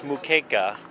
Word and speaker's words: moqueca: Well, it's moqueca, but I've seen it spelled with an O, moqueca. But moqueca. – You can do it either way, moqueca or moqueca.